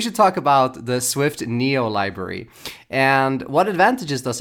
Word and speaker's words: NIO: N-I-O? – They told me that it's pronounced Neo, like N-E-O. – Oh, like Neo in the Matrix. – I was calling it N-I-O for a day.